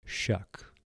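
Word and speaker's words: Choque: It's a French-Belgium name pronounced Chuck. No S, just a C like Chevrolet.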